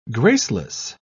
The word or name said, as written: graceless